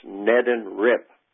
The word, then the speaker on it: Neddenrip: It's pronounced Neddenrip, letter rip.